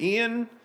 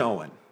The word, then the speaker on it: Eoin: For my entire life, I've been pronouncing it Ian, and it's Owen.